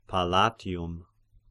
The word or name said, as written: Palatium